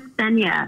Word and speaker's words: Nthenya: My name is Nthenya, like Nthenya from Kenya. – Okay, so the N is silent then. – Yeah, Nthenya okay.